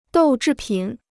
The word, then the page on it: 豆制品: dòu zhì pǐn